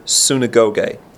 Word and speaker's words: synagoge: So the word synagogue is from the Greek word synagoge. And that word synagoge, you can spell it S-Y-N-A-G-O-G-E.